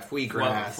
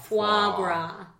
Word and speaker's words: foie gras: Which then Vinny corrects, but he still doesn't say it right. I think he says, like, fwee gras. – Yeah, for sure. – So you say it foie, right? – I believe it's foie.